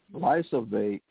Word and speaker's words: glyphosate: What to know about glyphosate.